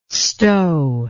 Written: Stowe

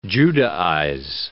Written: Judaize

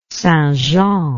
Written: St Jean

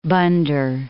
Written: Bandar

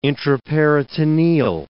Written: intraperitoneal

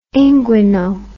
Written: inguino

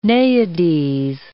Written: naiades

